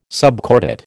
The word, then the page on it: subcordate: American English